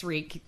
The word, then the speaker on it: Regan: Yes, Regan. Yeah. It's spelled the same way as Reagan, but it was not.